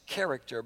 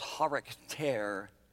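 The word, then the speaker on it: charakter (χαρακτήρ): It looks like it'd be pronounced character, but it's charakter.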